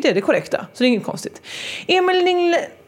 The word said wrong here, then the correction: Lignell: det uttalas däremot inte så. Emil Lignell heter han ju.